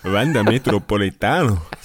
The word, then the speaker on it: Wanda Metropolitano: Wanda Metropolitano. So wird's ausgesprochen.